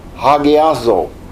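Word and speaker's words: hagiazo (ἁγιάζω): And so notice that the first one, which is usually pronounced hagiazo, and the H there should have a heavy to it. Hagiazo, okay? It means all these things.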